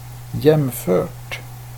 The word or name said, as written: jämfört